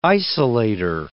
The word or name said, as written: isolator